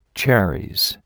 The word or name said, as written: cherries